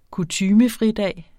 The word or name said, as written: kutymefridag